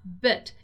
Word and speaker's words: bit: The word bit has this very unique sound, bit. It's something kinda like bit, or something between these two things, right? Bit. All right, it's different from beat.